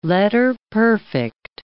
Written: letter-perfect

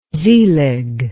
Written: zelig

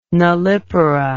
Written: nullipara

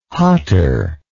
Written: hotter

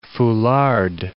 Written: foulard

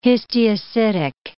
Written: histiocytic

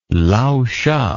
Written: lao she

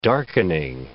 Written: darkening

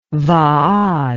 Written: vaad

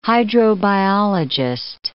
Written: hydrobiologist

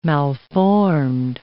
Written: malformed

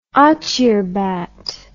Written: ochirbat

